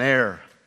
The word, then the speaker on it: heir: What is an heir? I'm very careful when I pronounce it because sometimes when I say it, I feel like I'm saying hair.